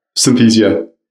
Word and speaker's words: Synthesia: He's the co-founder and CEO of Synthesia, spelled S-N-Y-T-H-E-S-I-A. Welcome to the program. – Thank you, Jason. Excited to be here. – Synthesia is how it's pronounced, correct? – Synthesia. – Synthesia, yeah. Okay.